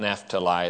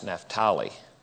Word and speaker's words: Naphtali: Like we say Naphtali for one of Jacob's sons, the tribe of Naphtali. And they don't call it Naphtali, it's Naphtali.